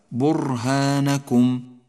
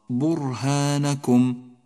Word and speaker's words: برهانكم: وأخيرا لاحظ كلمة برهانكم، برهانكم، حرف الراء ساكن، وإذا لم تتنبه إلى لفظه بشكل صحيح تكرر معك في النطق، أي هكذا برهانكم، والصواب برهانكم، أي اقرأ راء واحدة، ولونها الأزرق يدل على تفخيمها كما سيأتي معك في حلقاتنا المقبلة إن شاء الله تعالى.